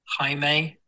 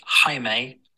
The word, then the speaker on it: Jaime: Jaime Alguersuari. So it's Jaime, like, hi mate. And then Alguersuari. Like a sh on Alguersuari or shwari, like a shawarma kebab.